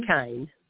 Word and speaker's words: DeaKyne: How do we pronounce this? DeaKyne? – DeaKyne. – Oh, I got it right.